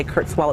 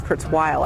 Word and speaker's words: Kurzweil: I want to make sure I pronounce his name, because I think a lot of people say Kurzweil. It's Kurzweil, I believe.